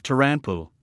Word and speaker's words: Toranpu: Moshi means if, and Tora is the abbreviation of Toranpu, the Japanese pronunciation of Trump, which is the same as Tora, the Japanese word for tiger.